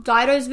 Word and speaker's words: Dido: Is that how you say it? Dido or Ditto? I used to say Ditto when I was little, but I think Ditto. It's Dido. But it's probably Dido, but I always said Ditto.